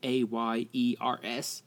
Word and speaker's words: Ayers: Um, he spells his name A Y E R S, but that's how you pronounce it. Ayers.